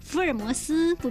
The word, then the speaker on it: 福尔摩斯: 纠正几个吃货青年的发音，福尔摩斯不必说成福尔莫斯，张三丰奶茶、夜市麻糍、黄胜记肉松要记得断句哦。